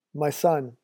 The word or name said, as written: my son